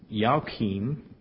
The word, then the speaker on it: Achim: Zadok begot Achim, which is pronounced Yaakim, and means Yahweh will raise.